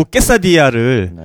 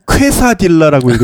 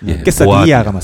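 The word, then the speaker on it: quesadilla: 그리고 케사디아를 네. 퀘사딜라라고 읽으시는. 아, 아 정말 아, 저 그 네. 네. 들을 때마다 너무 괴로워요. 케사디아가 네. 맞습니다.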